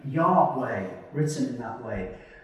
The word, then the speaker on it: Yahweh: It's the English translation of a Hebrew sound consisting of four letters. YHWH. And our best guess at its pronunciation is Yahweh.